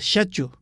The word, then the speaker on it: schedule: 例如， S C H E D U L E，排序这个字，英式的发音是 schedule，美式的发音是 schedule。